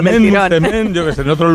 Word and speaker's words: msemen: Es una palabra complicada, es M-S-E-M-E-N. Msemen, ¿vale? Y se pronuncia como lo ha hecho Hakima. Pero nunca, nunca se pronuncia como lo vamos a escuchar en boca de este tiktoker, porque puede ocasionar confusiones.